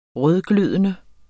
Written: rødglødende

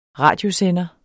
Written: radiosender

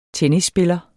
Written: TENNISSPILLER

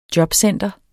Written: jobcenter

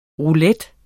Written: ROULETTEN

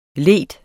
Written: leder

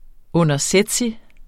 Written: undersætsig

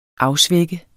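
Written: AFSVÆK

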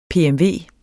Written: PMV

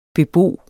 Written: bebo

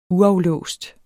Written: uaflåst

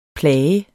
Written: plage

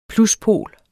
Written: pluspol

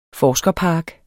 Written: forskerpark

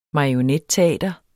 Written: marionetteater

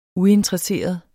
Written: UINTERESSEREDE

